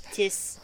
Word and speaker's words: Thiess: There's a man named Tiss. I'm not sure, you might have to help me with the pronunciation. T-H-I-E-S-S? – T-H-I-E-S-S. – Tiss. – Go for it.